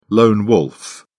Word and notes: lone wolf: British English

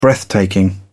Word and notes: breathtaking: British English

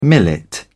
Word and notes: millet: British English

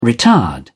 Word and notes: retard: British English

